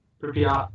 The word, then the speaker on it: Pripyat: Yeah, actually, there have been a number of reported hauntings around the area of people who toured Chernobyl and Pripyat. How do you say that word? Pripyat? – Pripyat. – Yeah, that.